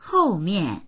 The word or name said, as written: hou4mian4